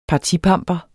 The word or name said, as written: partipamper